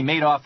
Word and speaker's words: Madoff: It's like he made off with the money. That's how you say his name.